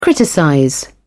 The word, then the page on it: criticize: British English